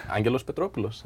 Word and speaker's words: Angelos Petropoulos: Did I come close to pronouncing that correct? – Pretty good. – How do you pronounce it? – Angelos Petropoulos. – That's perfect. Really good.